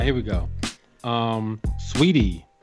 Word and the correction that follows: Saweetie: is that how you say your name? – It's I, Saweetie. – It's like Saweetie. – Saweetie.